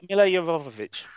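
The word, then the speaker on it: Milla Jovovich: Mila Jovovich. – Mila Jovovich.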